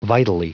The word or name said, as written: vitally